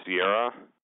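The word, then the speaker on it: CIERA: And that was the Center for Interdisciplinary Exploration and Research in Astrophysics, or is it pronounced Sierra? – Sierra, yeah. Good job.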